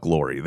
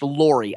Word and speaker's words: Lorre: If we don't, or is it Chuck Lorre or Chuck Lorre? – I think it's pronounced Lorre, I think. That's the way I've heard it pronounced.